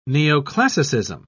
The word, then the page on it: neoclassicism: British English